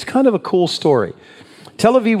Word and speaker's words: Tel Aviv: Tel Aviv. Tel Abib, it would be pronounced.